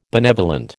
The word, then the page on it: benevolent: American English